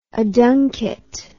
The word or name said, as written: aduncate